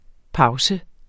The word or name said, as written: pause